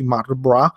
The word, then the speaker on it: Mardenborough: eh, che si chiama Jan Mardenbra eh, Mardenborough, che non ho mai capito perché in inglese si pronunci Marbra,